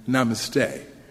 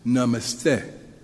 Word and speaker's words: namaste: The article informs us that American English speakers tend to attribute a shorter sound to the vowels and place the emphasis on the last syllable, as in namaste, what I have mistakenly been saying. But the correct pronunciation is namaste.